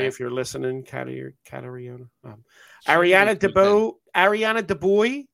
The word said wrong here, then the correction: DeBose: Am I saying that right? West Side Story? They just have DeBose.